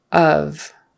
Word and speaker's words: of: So of, not off. Because O-F-F, off, is the opposite of on. Both have a short O sound. On, off, like the color olive. But of is not really an O sound. It's more like a U, a short U.